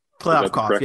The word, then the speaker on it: Kliavkoff: So, um, our boy George, we're going with Kliavkoff. – Kliavkoff, yeah.